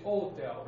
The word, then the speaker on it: hotel: Remember pronunciation? Hotel. Same way we say Portuguese. We say hotel, we don't say hotel, right? Same thing in English, hotel. – Okay. – So the second syllable is the one which is stressed, right? Hotel.